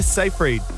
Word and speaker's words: Seyfried: And actress Amanda Seyfried has revealed no, she... No, you said it right.